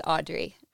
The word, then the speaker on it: Audre: Smelling the Wind by Audre Lorde. Did I pronounce her name right? It's Audre Lorde? – I think I looked that up too. I was also wondering, but it is Audre, as I found.